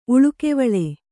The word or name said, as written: ಉಳುಕೆವಳೆ